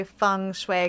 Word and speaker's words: feng shui: So the whole term is, the proper way to describe feng shui is to say feng shui, because that's how it's said in China.